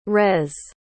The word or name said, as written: res